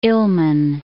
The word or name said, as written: Il'men'